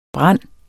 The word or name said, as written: brande